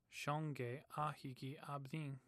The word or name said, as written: ahigi